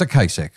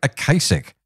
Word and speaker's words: Ocasek: And then when he died, I heard the news reports and went, oops, it's Ocasek. So let me get it right this time. Solo Rick Ocasek. Definitely how you pronounce it as part of the midday mixtape.